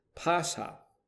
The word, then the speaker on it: Pascha: That is Strong's number 3957, which is pronounced Pascha.